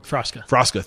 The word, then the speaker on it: Frasca: And just to kind of give a little more perspective or context, Bobby Stuckey and his business partner with Frasca, is it Frasca or I'm saying it wrong? – Frosca, thank you.